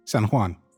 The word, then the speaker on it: San Juan: Do you say San... I don't know how you say it. J-U-A-N. – San Juan.